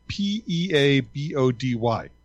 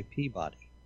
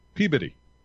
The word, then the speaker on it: Peabody: that's spelled P E A B O D Y? I would say Peabody. You would, and no one there would know where you meant. Of course not. Peabody. Peabody. And I said, "Oh, Peabody," and she goes, "But that's not how it's spelled." I go, "Well, if you want them to understand you, that's how you better pronounce it."